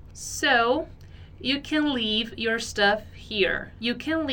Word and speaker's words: leave: E é importante a gente reparar muito também na pronúncia desse leave, que é um leave bem... leave. Ele tem um som mais parecido com o nosso I do português, só que um pouco mais prolongado.